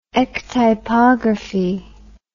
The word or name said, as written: ectypography